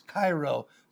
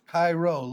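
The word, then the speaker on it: chairo: And in the Greek, it's kairo, C-H-A-I-R-O, C-H-A-I-R-O, kairo, long O at the end.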